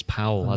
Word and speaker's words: Paul: He had a piece in the matchday programme where he even says this is how you pronounce my name. It's Powell, as in Powell.